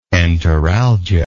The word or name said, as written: enteralgia